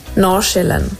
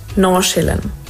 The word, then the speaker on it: Nordsjælland: Also eigentlich ist es gar nicht so eine Sache, aber man würde es so aussprechen. Nachschillen. Das D sage ich eigentlich nicht und das andere ist so Schä. Nachschillen. Ich hoffe, das hilft.